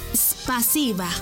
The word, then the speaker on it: Спасибо: En ruso, gracias se dice spasiba. ¿Cómo? Spasiba. Una vez más. Spasiba. Y se escribe spasiba. S-P-A-S-I-B-A.